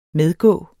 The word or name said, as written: medgå